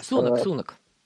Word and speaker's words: Сунак: А поскольку все знают, что там этот тигр есть, то Риши Сунак понимает, что... Сунак, прошу прощения, Сунак. Никак с его ударением не разобраться. – Вот. Сунак. – Сунак, да.